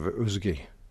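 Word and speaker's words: uisge: Ah, how I recall the first time I fell for the seduction of usgi, as it's pronounced in Celtic, or as we more usually refer to it, whisky.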